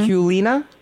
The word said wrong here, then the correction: Kuleana: Kuleana. – That's pretty good.